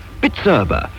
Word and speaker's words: Bizerba: B-I-Z-E-R-B-A. Pronounced Bitzerba.